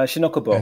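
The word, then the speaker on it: Shin-Ōkubo: There's Shin Okubu, Okubo. – Uh, Shinokubo.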